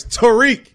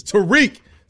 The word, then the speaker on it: Tarik: First of all, put some respect and say his name right. Tarik. – Whatever. Tarik Skubal is what I call him. – That's what everybody taught. Tarik. It's Tarik. Sound like he was in the hood. It's Tarik.